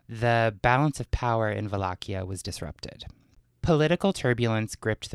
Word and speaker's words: Wallachia: Upon his trusted ally, Prince Mircea the Elder of Wallachia. – Wallachia. – I know Wallachia. – That sounds like a cool, that's a cool name. – There's different ways to pronounce it. That's the actual Romanian pronunciation.